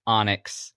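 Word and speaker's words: Anax: Time to say, if you're listening and you're struggling, because this happens all the time, it's pronounced Onyx.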